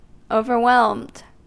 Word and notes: overwhelmed: American English

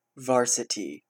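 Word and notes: varsity: Canadian English

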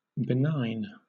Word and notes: benign: British English

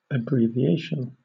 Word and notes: abbreviation: British English

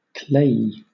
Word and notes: clayey: British English

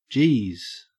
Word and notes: jeez: Australian English

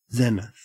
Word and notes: zenith: Australian English

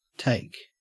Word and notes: take: Australian English

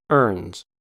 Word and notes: ernes: American English

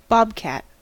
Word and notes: bobcat: American English

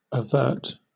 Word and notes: overt: British English

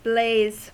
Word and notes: blaze: American English

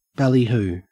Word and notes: ballyhoo: Australian English

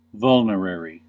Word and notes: vulnerary: American English

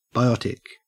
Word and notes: biotic: Australian English

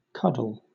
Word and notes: cuddle: British English

